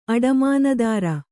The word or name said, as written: ಅಡಮಾನದಾರ